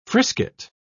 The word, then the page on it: frisket: British English